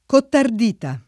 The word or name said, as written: cottardita